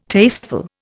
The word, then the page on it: tasteful: Indian